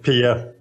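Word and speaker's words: Piye: Yeah, I just learned that I probably have pronounced it wrong in the YouTube show. It's Pia.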